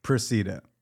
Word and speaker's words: Procida: Proceda.